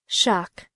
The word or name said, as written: shock